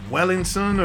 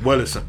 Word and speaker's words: Wellison: Wellison, and I was keep saying his name wrong, you know. – What were you saying it like? – Yeah, I was like Wellington or whatever, and he's like Wellison, and I was drunk.